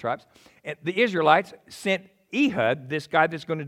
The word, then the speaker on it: Ehud: His name was pronounced correctly as Ehud, son of Gira.